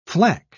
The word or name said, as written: flak